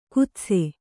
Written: ಖೇದಿಸು

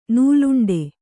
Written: ನೂಲುಂಡೆ

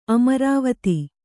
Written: ಅಮರಾವತಿ

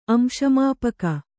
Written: ಅಂಶಮಾಪಕ

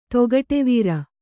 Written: ತೊಗಟೆವೀರ

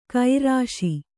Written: ಕಾರೀಷ

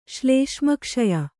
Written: ಶ್ಲೇಷ್ಮಕ್ಷಯ

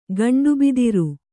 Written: ಗಂಡುಬಿದಿರು

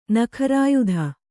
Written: ನಖರಾಯುಧ

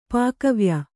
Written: ಪಾಕವ್ಯ

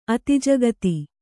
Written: ಅತಿಜಗತಿ

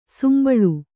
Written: ಸಂಬಾಳ

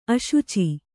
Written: ಆಶುಚಿ

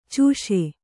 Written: ಚೂಷೆ

